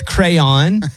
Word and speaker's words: crayon: A crown. – I'll accept it. You said crown, but it's crayon.